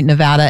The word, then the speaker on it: Nevada: I have to always remember, if I had gone to Nevada, that's how I remember how to say it correctly. Idaho, Missouri, Nevada, and Virginia have ballot initiatives that are abortion related.